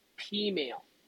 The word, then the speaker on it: peameal: Did you just call it penile? – Pea meal. – You got to spell that. – P-E-A-M-E-A-L. – Yeah, that's what I thought you said.